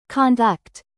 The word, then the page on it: conduct: American English; verb